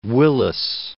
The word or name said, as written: Willis